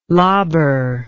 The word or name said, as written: lobber